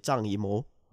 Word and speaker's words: Zhang Yimou: Não culpo, porque não é fácil, o nome dele é Zhang Yimou.